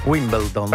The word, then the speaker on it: Wimbledon: Senti come l'ho detto bene, Wimbledon. – Sentire, fa sentire? – Wimbledon. – Bravo.